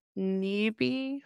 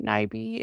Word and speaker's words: Nyby: So Tristan Nyby, hopefully I said that right, Nyby, it's N-Y-B-Y.